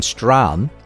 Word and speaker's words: Strachan: Owen Strachan, well, he's not actually pronounced Strachan, I've discovered, it's Owen Strahan, is author of Christianity and Wokeness, How the Social Justice Movement is Hijacking the Gospel and the Way to Stop It.